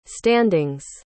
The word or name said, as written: standings